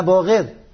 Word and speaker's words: باقر: حضرت فهمود اشتباه تلفظ میکنی، انا باقر، نه من بقر تلفظ کردم.